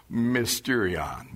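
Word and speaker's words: Mysterion (μυστήριον): Let me pronounce the word mysteries in Greek, sort of. Mystery. You know any words in English sound like that? Mysterion. Mystery. That's where we get the word mysteries from, this Greek word.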